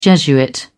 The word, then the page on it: jesuit: British English